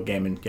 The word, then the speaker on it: Gaiman: I just finished a book called Dry by Neil Gaiman. I think that's how you pronounce the last name. – Neil Gaiman, yes.